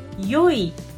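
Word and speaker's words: yoi (よい): It's actually a bit old-fashioned way to pronounce E. So maybe you know E. So E means good, okay? And then, but historically, we pronounce the word good, E, as yoi, okay? And then in this greeting, we use historical pronunciation, means good.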